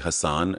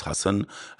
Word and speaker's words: Hassan: People say, they say Hassan, and it's Hassan, and I'm so particular about it.